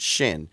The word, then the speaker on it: Xin: He spells it X-I-N, but you pronounce it Shin.